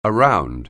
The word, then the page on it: around: anglais